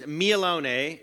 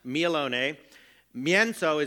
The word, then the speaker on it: mielone mięso: And in Polish, it is mielone is ground. Mielone. Mięso is meat. So mielone mięso.